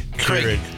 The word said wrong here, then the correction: Keurig: Keurig. Yeah. You don't even know how to say it.